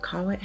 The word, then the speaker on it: kawaii: They're the kawaii. How do you say it? – Are you trying to say kawaii? – That's it. – Cute. That means cute in Japanese.